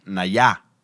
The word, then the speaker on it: Nayá: El mezcal se llama Nayá, porque tiene acento en la A, la segunda A.